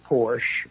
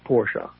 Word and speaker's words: Porsche: Well, if you're in Germany, it's Porsche. – Okay. – We Americanize it and say Porsche, but it really is pronounced Porsche.